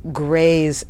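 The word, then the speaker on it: GRASE: And one other clarification of terms, you gave two categories and you said one was grays and non-grays. And we should say that's G-R-A-S-E, not G-R-A-Y or G-R-E-Y-S. Just briefly, what is grays stand for?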